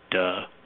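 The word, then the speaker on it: duh (德): They also all thought you had, when you were in that state, this power that unfortunately in modern Mandarin is pronounced duh, which my students always kind of laugh at when I say in class. That's unfortunately how it's pronounced.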